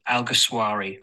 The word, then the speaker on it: Alguersuari: Jaime Alguersuari. So it's Jaime, like, hi mate. And then Alguersuari, like a sh on Alguersuari, or shwari, like a shawarma kebab.